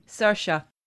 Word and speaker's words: Saoirse: We knew that, I'm always hoping that I pronounce it right, Cersei? – Saoirse. – Yeah, Saoirse. Yeah.